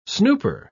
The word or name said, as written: snooper